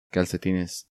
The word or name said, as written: Calcetines